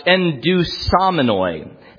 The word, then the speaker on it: endusomenoi: A Greek word which would be pronounced endusomenoi. Endusomenoi.